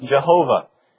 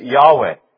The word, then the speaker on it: Yahweh: For a long time, it was pronounced "Jehovah" in English, and you still hear "Jehovah" at times. That was really sort of a strangely contrived pronunciation. Now, the best guess is Yahweh. Yahweh is maybe how it was pronounced, but Yahweh is probably the most proper way to say that.